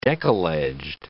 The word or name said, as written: deckle-edged